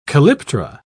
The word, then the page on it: calyptra: British English